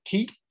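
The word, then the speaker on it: ki (気): In Japanese, it's pronounced ki.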